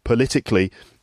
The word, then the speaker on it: political: And then the adjective political, political. The word stress is on the second syllable, political.